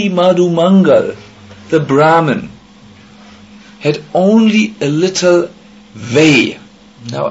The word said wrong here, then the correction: whey: Is it the right pronunciation? Vay? When you churn butter from milk, you get the butter and... – Way.